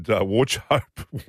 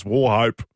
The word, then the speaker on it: Warchope: I said Warchope, which is how it's spelled. It's War Hope. – The CH is silent.